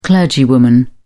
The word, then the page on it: clergywoman: British English